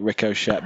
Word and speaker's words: Ricochet: That's how you say it, isn't it? Ricochet. – Yep. Sure.